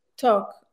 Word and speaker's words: Tok: How is it pronounced? It's not like TikTok, it's Tok. – Tok. – Mm-hmm. Tok. – Talk. So my full name is Sever Tok, S-E-V-E-R, which means to love.